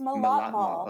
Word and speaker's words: Millatmal: Alexandra, am I pronouncing that last name right? – It's Millatmal. – Okay, sorry. I totally try to like Afghanize it. Millatmal.